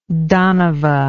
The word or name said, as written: danava